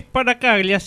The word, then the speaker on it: Sparacaglia: Es Sparacaglia, sí, un apellido de origen vasco-francés.